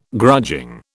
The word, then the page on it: grudging: British English